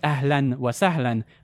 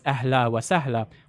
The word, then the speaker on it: ahlan wa sahlan: I said it's supposed to be pronounced ahlan wa sahlan, and the film, she just says ahla wa sahla, which is a dialect that you get in North Jordan, Palestine, southern Lebanon.